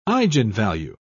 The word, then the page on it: eigenvalue: British English